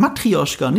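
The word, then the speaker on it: Matrjoschka: Oder richtig ausgesprochen, du kannst es natürlich. Heißt wirklich Matrioschka, nicht Matroschka.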